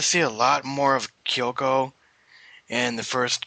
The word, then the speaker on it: Kyouko: Kyoko. I think you say her name. K-Y-O-U-K-O. Kyoko? – What? – Kyoko? K-Y-O-U-K-O. – Kyoko? – Kyoko, that's how you say it.